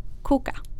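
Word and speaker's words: KUKA: Men den som jag trodde hade gått sämst, men jag fick det bara till -4%, det är, nu ska jag säga det med tyskt uttal, Koka.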